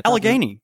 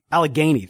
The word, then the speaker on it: Allegheny: Allegheny. Allegheny.